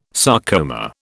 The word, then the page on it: sarcoma: British English